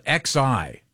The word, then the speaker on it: Xi: They spell it X-I. – Yeah. – But it's this says it's pronounced Xi.